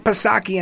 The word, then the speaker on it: Psaki: Of course, Jen Circle Back Psaki, and that's silent P, of course.